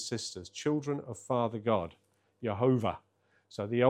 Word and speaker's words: Jehovah: And he was convinced that it should be pronounced Jehovah. So that was interesting.